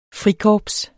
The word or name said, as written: frikorps